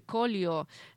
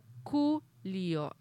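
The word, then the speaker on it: Coolio: Gangster Paradise, del cantante, vamos a pronunciarlo como se escribe Colio. En realidad se pronuncia Culio, así, con acento en la U, pero lo vamos a pronunciar como se escribe Colio.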